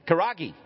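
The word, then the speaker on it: Karahi: Karagi. Did I say it wrong? It's Karagi chicken.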